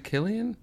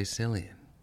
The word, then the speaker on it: Cillian: Is it Killian? – Yes. – Fuck, I always say Cillian. Fuck. – Cillian Rail. – Pronounces his C's.